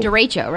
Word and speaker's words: derecho: So Last night I was trying to pronounce this difficult word, derecho. Derecho? – Derecho, right? – Derecho.